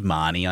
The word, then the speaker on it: Mahoney: It's actually Reed Marnie, I think. That's how you I think it is. Marnie. – Yeah, because it sounds... – No, I say it's spelt Mahoney, and that's how you're supposed to say that name. – So yeah, but it is Marnie, which is why...